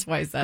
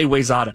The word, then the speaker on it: Wayzata: It is Wyzetta. – Wyzetta, thank you. She's always screw me up and say Wayzata.